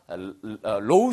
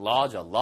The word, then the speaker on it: law: 이걸 법대라고 할 때 우리가 low school 그러면 낮은 이란 뜻이에요. 그러니까 그게 아니고 law school. 이건 law죠. law.